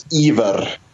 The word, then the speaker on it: Ivar: I said Ivar, and I apologize. – It's no, it's totally fine. Like I've heard, I guess the Scottish pronunciation would be Ivar, but Norwegian is Ivar.